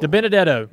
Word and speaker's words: DiBenedetto: De Benedetto.